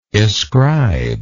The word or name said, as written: escribe